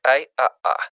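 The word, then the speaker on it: Ай Аа (艾AA): Напоминаем, что имя составлено из китайского и английского элементов и произносится как Ай Аа.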